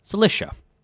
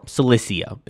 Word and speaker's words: Cilicia: Looming in their way were the Taurus Mountains, which cut the plateau off from the lowland coastal region to the southeast, known as Cilicia. Or Cilicia, if you prefer that pronunciation.